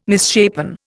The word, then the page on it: misshapen: Indian